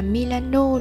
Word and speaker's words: Milano: thành phố Milan Đọc theo đúng kiểu người Ý phải là Milano đúng không?